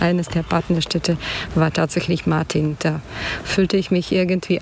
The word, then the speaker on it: Martin: Martin, spricht man das? – Ja, Martin.